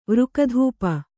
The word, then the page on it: ವೃಕಧೂಪ: vřka dhūpa